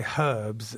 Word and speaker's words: herbs: You know how we say herbs and you say herbs? – Yeah, we don't really pronounce any of the H.